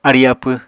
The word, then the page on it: arí-apw: Apatani